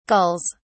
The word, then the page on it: gulls: British English